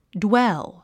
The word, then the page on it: dwell: ドゥエル